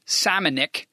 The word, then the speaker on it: Šamanić: It's spelled Šamanić, but apparently in Croatia, it's pronounced Šamanić, right?